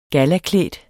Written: gallaklædt

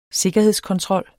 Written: sikkerhedskontrol

